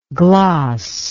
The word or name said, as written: glossingly